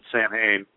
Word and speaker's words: Samhain: A lot of people think the big pagan holiday would be Halloween or Samhain or whatever the hell they say it, Samhain. If you're cool, you pronounce it Samhain.